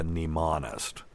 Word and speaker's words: mnemonist: Now, if you want to read a book about a guy that had an insane memory, read uh, Mind of a Mnemonist. And if you're looking hmm. it up, remember mnemonist is, starts with a silent M for the people who are out yeah.